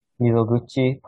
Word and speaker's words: Mizoguchi: I didn't think you fell in love with Mitsuguchi. Or how is his name pronounced? – Mizoguchi, played by Goro Fushitani.